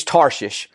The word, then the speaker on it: Tarshish: I said, surely there's a better way to pronounce that. There's not. It's Tarshish. Now, that's modern day.